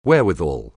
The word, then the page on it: wherewithal: anglais